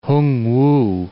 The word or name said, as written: Hung-wu